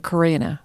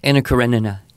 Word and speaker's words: Karenina: And we actually had that conversation with Anna Karenina. See, I'm not even sure I'm saying it right. – Anna Karenina. – Anna Karenina. – Karenina.